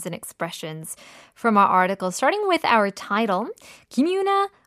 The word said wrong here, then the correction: 김연아: 어, 김연아. I always say it wrong, right? 김연아.